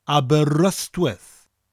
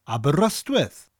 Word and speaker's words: Aberystwyth: Now, in the word Aberystwyth, we have the letter Y, and English-speaking people tend to pronounce that letter Y as an I sound if they don't speak Welsh. But that Y has an A sound, Aberystwyth.